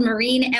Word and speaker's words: Myrin: My last name is spelled Marine, M-Y-R-I-N.